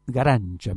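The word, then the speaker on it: Garanča: Nosotros tenemos acá algunas grabaciones de Lina Garanza. Y Garancha, mejor dicho, así se pronuncia.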